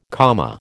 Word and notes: Kama: American English

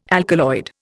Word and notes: alkaloid: Indian

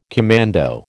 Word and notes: commando: American English

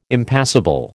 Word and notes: Impassable: American English

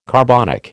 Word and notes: carbonic: American English

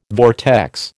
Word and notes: vortex: American English

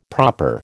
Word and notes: proper: American English

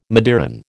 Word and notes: midiron: American English

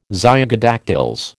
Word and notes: zygodactyls: American English

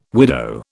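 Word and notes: widower: British English

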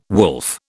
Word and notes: wolf: British English

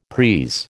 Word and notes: prise: American English

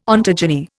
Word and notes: ontogeny: Indian

